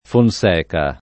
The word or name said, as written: Fonseca